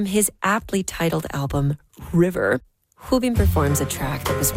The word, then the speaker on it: Rubim: This would be a good moment to mention that Edmonton's Rubim de Toledo is originally from Brazil, where the letter R at the beginning of a word is often pronounced more like an H.